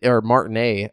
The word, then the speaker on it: Martinet: You know, I didn't expect this, but Nintendo has confirmed that Charles Martinet, Martinet, I think is how it's pronounced, the original voice actor of Mario, who has played the character since 1991.